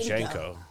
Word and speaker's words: JNCO: I think, is it pronounced Jinkos or Junko? – I always said Janko. Janko. But that doesn't make sense because it's J-N-C-O. – J-N-C-O. – So it'd be Jinko. – It would Jinko.